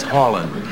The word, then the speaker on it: Harlan: my name is Harlan. That's with an N.